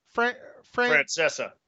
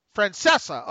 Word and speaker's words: Francesa: Francesa. – Francesa.